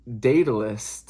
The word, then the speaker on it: Daedalus: So they're like, we need to find, I think you say his name, Daedalus? Hold on. It's like D-A-E-D-A-L-U-S. Daedalus.